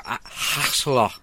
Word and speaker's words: axolotl: It's a Mexican salamander called an axolotl, or axolotl, I think. – Axolotl. – Yeah, is the way to pronounce it, but...